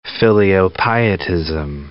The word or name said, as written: filiopietism